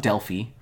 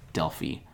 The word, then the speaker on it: Delphi: I think it'd be Delphi. – Really? – Yeah. – I always have said Delphi. I don't know. – The I is usually pronounced with an E sound. – Oh, okay. – With those languages. So. – Even at the end of a mm-hmm.